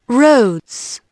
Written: roads

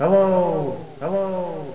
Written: hello-o

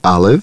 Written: Olive

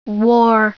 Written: wore

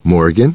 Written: Morgan

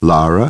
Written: Lara

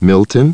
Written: Milton

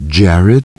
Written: Jared

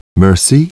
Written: Mercy

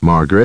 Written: Margaret

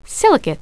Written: silicate

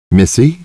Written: Missy

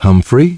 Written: Humphrey

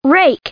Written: rake

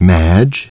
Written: Madge

